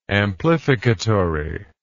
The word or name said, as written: amplificatory